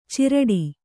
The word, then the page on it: ಚಿರಡಿ: ciraḍi